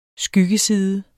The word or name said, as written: skyggeside